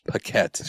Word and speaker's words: Paquette: I thought it was Paquette. – Sure, we'll go with that. – Think of like packet, but like the emphasis is on the E. – Paquette. – No. – Like spaghetti. – How do you spell packet? – P-A-C-K-E-T.